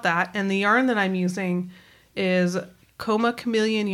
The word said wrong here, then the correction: Comma Chameleon: Yarn Co. Did I say that right? – Comma Chameleon.